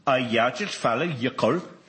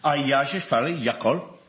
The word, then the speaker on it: Eyjafjallajökull: Eyjafjallajökull. – What was that again? – Eyjafjallajökull.